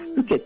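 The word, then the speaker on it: 深井: このおふけ焼きとはですね、名古屋城内で焼かれてたですね、いわゆる殿様のお庭焼きってものでですね、で、ふけってのは、深いって字に、井戸の井って書いて、これふけって読むんですけど、これはですね、もともとの意味は沼地とか湿地帯のことを指す言葉なんですね。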